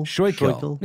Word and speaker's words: Schuylkill: The Schuylkill River? How would you say that? – Schuylkill. – Yeah. I want you to throw it in the watery depths of the Schuylkill.